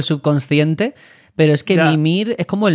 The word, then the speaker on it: Mimir: Pues a veces pronuncian su nombre como Mimir y otras Mimir. La verdad, y los mismos personajes lo pronuncian Mimir. Bueno, es que no se acaban de aclarar, no tendrían una indicación clara. Cory Barlog en las entrevistas le llama Mimir.